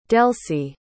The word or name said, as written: delcie